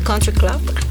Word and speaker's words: Country Club: Conocido mejor como el Country Club ¿Sí lo dije bien? – Sí, perfecto.